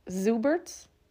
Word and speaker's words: Zuberts: So then, in early December of 2022, 21-year-old Christoph Zuberts, do you pronounce the Z? – Yes. – Zuberts body was pulled from Lake Michigan.